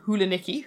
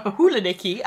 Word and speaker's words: Hulanicki: So Bieber, as in the 60s and 70s British-based fashion label started by Barbara Hulanicki, who has possibly the best surname ever. – It's up there. That was good pronunciation as well. – Hulanicki, and actually Rachel Coopy designed a pair of socks for the issue, which we named after Barbara Hulanicki.